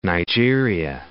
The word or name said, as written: Nigeria